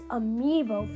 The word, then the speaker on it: Amiibo: And I know that Ambio Festival. Oh, it's Amiibo Festival. I've always been pronouncing it wrong then.